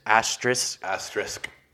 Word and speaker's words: asterisk: Asterisk. – It's asterisk. – Yeah, SK.